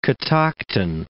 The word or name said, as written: Catoctin Mountain